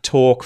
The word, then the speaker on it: talk: There are words where L appears in the spelling of words like walk, talk, folk, and half. However, the L is not pronounced, okay?